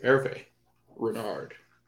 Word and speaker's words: Hervé Renard: Hervé Renard.